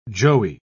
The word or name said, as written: joey